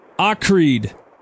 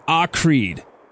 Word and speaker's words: Hacride: Acride. It's spelled H-A-C-R-I-D-E. Acride.